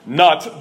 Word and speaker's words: Nut: This was an attack on the Egyptian deity called Nut, and it's spelled exactly like it sounds, N-U-T, Nut, the Egyptian goddess of the sky.